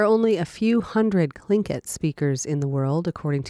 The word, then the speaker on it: Tlingit: It's how I've always learned growing up. Grandma, mom, uncles, they always said it like that. Tlingit. – The name is Tlingit.